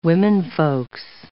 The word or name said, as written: womenfolks